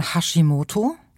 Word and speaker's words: Hashimoto: nämlich zum einen den sogenannten Hashimoto.